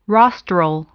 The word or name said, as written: rostral